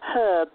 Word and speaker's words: herb: So he says Herb. – Herb. – They yeah. – pronounce the H. – Yeah. – Where we, it's silent for us Americans.